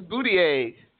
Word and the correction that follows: Buttigieg: It's actually Buttigieg.